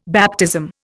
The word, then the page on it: baptism: Indian